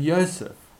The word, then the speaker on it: Yosef: Of course, in the Aramaic of the time, they were pronounced Yeshua and Yosef.